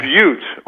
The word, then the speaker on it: Butte: Butte. – Okay, yeah. Butte, only 80 miles away from you, is the largest Superfund site in the United States of America.